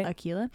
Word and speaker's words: Del Aquila: His name is David Del Aquila, by the way. I think I'm pronouncing that right. A-Q-U-I-L-A. – Sounds right. – Del Aquila.